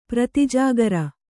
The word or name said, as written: ಪ್ರತಿಜಾಗರ